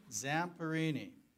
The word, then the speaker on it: Zamperini: Zamperini, who was in the Olympics in Berlin before World War II.